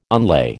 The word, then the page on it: unlay: American English